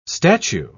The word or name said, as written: statue